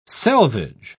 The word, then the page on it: selvage: British English